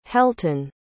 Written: helton

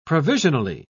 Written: provisionally

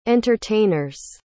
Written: entertainers

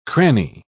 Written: cranny

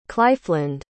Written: clyfland

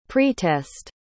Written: pretest

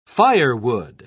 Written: firewood

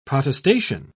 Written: protestation

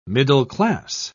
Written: middle-class

